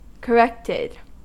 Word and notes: corrected: American English

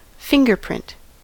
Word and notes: fingerprint: American English

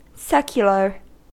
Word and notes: secular: American English

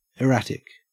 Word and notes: erratic: Australian English

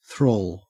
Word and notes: thrall: Australian English

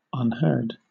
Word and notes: unheard: British English

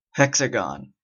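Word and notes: hexagon: Canadian English

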